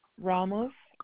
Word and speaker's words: Ramos: Amada, AMADA, Ramos, RAMOS, Garcia, GARCIA.